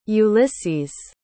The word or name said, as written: ulises